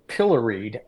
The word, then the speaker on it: pilloried: But I, one of the people who got uh, pilloried, uh, uh, which rhymes with Hillary, uh, but was was Strzok, and you know that's a special case.